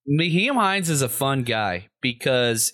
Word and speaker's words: Nyheim Hines: But I'm going to try the name, Nyheim Hines. Please don't not stop listening to the show because I didn't say his name right. – I think you said it right.